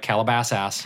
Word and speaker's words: Calabasas: Calabasas and... – Say it right. Calabasas. – Calabasas.